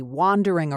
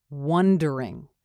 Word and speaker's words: wandering: First one, wandering versus wondering. Be careful with the vowel sound in here because it'll completely change the meaning of the word. We want wandering. Wandering, not wondering.